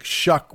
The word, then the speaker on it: Shough: If you're reading about the game this week, you'll see the name spelled S-H-O-U-G-H, which is not what you would think Shuck would be spelled like, but nevertheless, it's Tyler Shuck, just for the record.